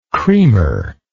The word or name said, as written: cremer